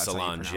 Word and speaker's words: Solange: I hope I believe I hope it's Solange.